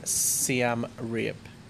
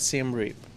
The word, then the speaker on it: Siem Reap: How do you say that? Siem Reap. – I don't know how to say it in the English pronunciation, but in Thai it's Siem Reap, right? – Siem Reap. Yeah, that's how I know in Thai, but in English it would be Siem Reap?